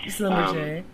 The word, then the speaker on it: Schlumberger: And so you are currently working at, and we just talked about this, Schlumberger. Yes, so I pronounced it correctly. – Yeah. Schlumberger.